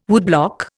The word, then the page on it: woodblock: Indian